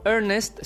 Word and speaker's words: Ernest: Ernest, que es la última palabra del título, fonéticamente se pronuncia igual, pero según se le varíe o no una consonante, una vocal, perdón, cambia el significado. Por ejemplo, Ernest significa tanto Ernesto como nombre de pila y a la vez significa serio o formal como adjetivo.